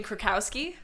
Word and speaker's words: Krakowski: Jane Krasowski. I love them all. Well, yeah. However you say her name. – Jane Krakowski. – Or Krasowski, like I said. – You're thinking of John Krasinski.